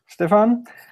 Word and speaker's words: Stefan: Stefan Scherer, did I pronounce that right, Stefan? – Yes, that's correct, John. – Brilliant. You want to go ahead and introduce yourself? – Yes, I'm Stefan, and I'm a software engineer here in Germany in a small software company.